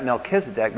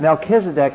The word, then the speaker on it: Melchizedek: because verse 18 could refer to the fact Melchizedek, the Melech, the M-L-C, C-H, that kuh, that hard C-H. M-L-C-H in Hebrew is king.